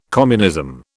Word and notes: communism: British English

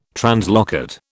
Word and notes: translocate: British English

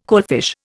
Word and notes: coalfish: Indian

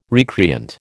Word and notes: recreant: American English

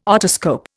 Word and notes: otoscope: Indian

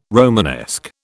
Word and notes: Romanesque: British English

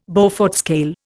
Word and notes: Beaufort scale: Indian

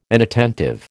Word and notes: inattentive: American English

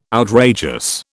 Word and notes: outrageous: British English

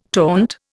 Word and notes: taunt: Indian

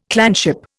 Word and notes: clanship: Indian

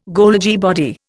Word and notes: Golgi body: Indian

